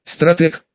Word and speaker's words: стратег: Да ты, оказывается, стратег», это слово Селиванов произнес с ударением на последний слог.